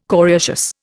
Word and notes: coriaceous: Indian

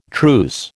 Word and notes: truce: American English

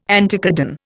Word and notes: anticodon: Indian

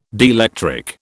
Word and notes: dielectric: British English

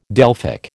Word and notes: Delphic: American English

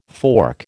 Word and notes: fork: American English

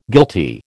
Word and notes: guilty: American English

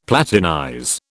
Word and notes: platinize: British English

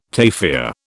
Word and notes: tafia: British English

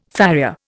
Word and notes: farrier: Indian

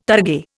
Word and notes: targe: Indian

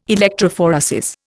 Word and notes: electrophoresis: Indian

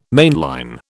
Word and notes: mainline: British English

